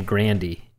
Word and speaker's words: Grande: And her last, she pronounces her last name Grandy, is how she pronounces her last name.